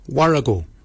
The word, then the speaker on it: worragol: Baken is spelled B-A-G-I-N-Y. Baken. Baken is your beard. The upper mouth, we say warrigal. Warrigal. Warrigal is spelled W-O-R-R-A-G-O-L.